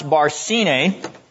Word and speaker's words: Barsine: That's pronounced Barsine.